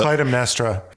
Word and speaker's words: Clytemnestra: How do you say it? – Clytemnestra. – Clytemnestra, okay.